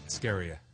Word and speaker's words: Askarieh: Ascaria. – Ascaria? – Yes. – What is that? How do you spell it? – A-S-K-A-R-I-E-H. – E-H. So, but that H gives it that yes. lift at the end.